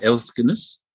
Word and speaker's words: Elksnis: Elksnis? – Yeah, that's fine.